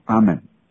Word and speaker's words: 阿们: 相信在旧约的希伯来文里面就是阿们，它的发音就是阿们。